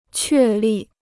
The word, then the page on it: 确立: què lì